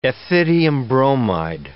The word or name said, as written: ethidium bromide